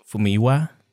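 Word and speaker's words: Famuyiwa: But what this show did behind the scenes with the new technology and the talents involved, you got Deborah Chow, Taika Waititi, Fumiyewa, I can never pronounce that right. – Fumiwa.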